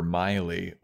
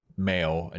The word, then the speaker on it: Maile: miley which is spelled m-a-i-l-e mm-hmm. uh, which was a bit of a disagreement between Hannah and Fran because Fran pronounces it male, and she's like, she hates that. Her yeah. name is Miley.